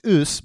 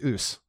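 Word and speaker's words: A'ass: But it's pronounced ouse, but it is it is A apostrophe A S S.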